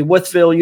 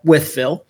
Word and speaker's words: Wytheville: So all this started in 1987 in a small southwestern Virginia town called Wytheville. – Spelled weird as fuck. I got to throw that one in there. – Yeah, it's spelled Wytheville. So it looks like Wytheville, but it's actually with.